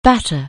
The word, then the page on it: batter: anglais